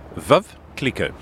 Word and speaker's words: Veuve Clicquot: Veuve Clicquot.